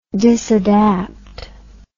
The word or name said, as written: disadapt